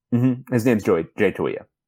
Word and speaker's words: Toia: We've always said Jay Toia. Did he change it when he went to UCLA? – So the trick with Polynesian names is just pronounce every letter.